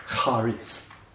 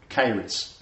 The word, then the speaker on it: charis: Now some will say charis, and some will say charis, as I found on the pronunciation just recently on Google.